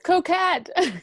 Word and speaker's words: Cocquyt: On today's episode, we have another great guest, Anne, and I'm going to slaughter your last name, so I'll try and say it, Cocquyt. I don't know. – Just Cocquyt.